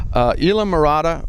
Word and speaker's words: Islamorada: And I'm here with the area general manager for the Isla Mirada Resort Collection, or Isla. How do we pronounce that again? – Isla Mirada. – Isla Mirada.